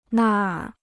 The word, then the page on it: 那儿: nàr